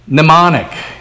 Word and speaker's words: mnemonic: Mnemonic. Not demonic, but mnemonic, with a silent M on the beginning.